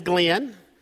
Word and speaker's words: Glenn: You know, my name's Glenn.